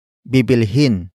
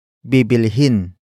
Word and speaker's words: bibilhin: Bibilhin nila ang bahay sa Cebu. The first word is bibilhin. Bibilhin. Bibilhin. Bibilhin is spelled as B I B I L H I N.